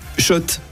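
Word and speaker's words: Schott: Oui, Shot, et pas Scott. Ça fait des années qu'on prononce mal la marque.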